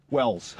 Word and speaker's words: Wells: Wells.